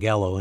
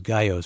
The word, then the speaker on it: Gallo: I've been saying Gallo, and it should be pronounced Gallo, so my apologies, Councilman and head of the Public Safety Committee with the Oakland City Council.